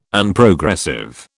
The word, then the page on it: Unprogressive: British English